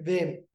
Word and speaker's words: Wim: Yeah, you know, at this stage I respond to anything, but it's in Holland we say Vim. – Vim De Jongh. Okay, there we go. Because I know Jong, J-O-N-G-E, I've done my research, means young, doesn't it? – It does, yeah.